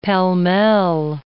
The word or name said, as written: pall-mall